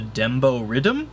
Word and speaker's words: dembow riddim: So the rhythm, the musical characteristic of reggaeton, apparently is called the dembo rhythm. – Huh. Okay. – Spelled D-E-M-B-O-W-R-I-D-D-I-M. Dembo rhythm. Which, let's see what that's all about. Rhythm is the Jamaican patois pronunciation of the English word rhythm.